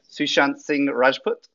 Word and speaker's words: Sushant Singh Rajput: Sushant Singh Rajput, is that correct? – Correct.